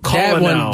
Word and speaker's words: colonel: It's like the word colonel is spelled colonel.